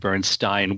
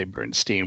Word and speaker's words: Bernstein: Yes, and we are talking about Leonard Bernstein, which apparently, from all I've read, was his preferred pronunciation, although many people say Bernstein, which I guess is an Americanization of it.